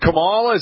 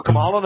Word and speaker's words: Kamala: For Kamala, no? – Yeah, because if you just think of this song. – Boy George. – Chameleon, you know.